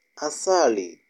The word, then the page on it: asali: Kiswahili